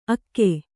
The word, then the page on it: ಅಕ್ಕೆ: akke; Kannada